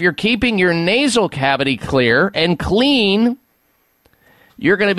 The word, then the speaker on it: Xlear: Clear is spelled X-L-E-A-R. The X stands for xylitol.